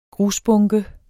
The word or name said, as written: grusbunke